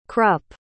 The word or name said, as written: Krupp